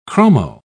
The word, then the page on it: chromo: British English